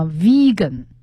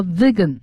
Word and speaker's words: vegan: 那么这个素食主义还有一个英文的词叫 vegan，它有三个读音啊，vegan 和 vegan 和 vegan，这是很严格的素食主义者的意思。